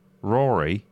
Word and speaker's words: Ruairi: Rory. That's just pronounced Rory. – Is it pronounced Rory? – Yeah. – Really? – It's a really interesting, different way of. I've seen it spelled like that once before. – Hmm. – R-U-A-I-R-I, Rory.